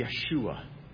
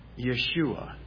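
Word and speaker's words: Yeshua (יֵשׁוּעַ): If you were to pronounce Joshua's name in Hebrew, it would be Yeshua. If you were to pronounce Jesus' name in Hebrew, it would be Yeshua.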